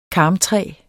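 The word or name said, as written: karmtræ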